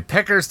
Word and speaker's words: Pecker: So anyway, Pecker's taking pictures of things. – Pecker! You're not saying it right. – Yeah, pop the P, Chris.